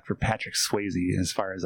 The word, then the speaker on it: Swayze: Yeah, Swayze rhymes with hazy.